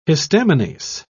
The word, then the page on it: histaminase: British English